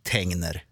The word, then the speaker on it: Tengner: För det är ju så att det har aldrig funnits någon sån här liten apostrof på e och det finns två n, n, g, n. Så det kan bara uttalas Tegner.